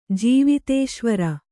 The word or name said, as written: ಜೀವಿತೇಶ್ವರ